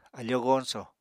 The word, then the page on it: afóo goñsò: Apatani